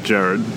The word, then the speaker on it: Gerard: She says Gerard.